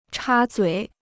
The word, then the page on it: 插嘴: chā zuǐ